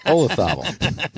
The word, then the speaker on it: Olazabal: Olazabal.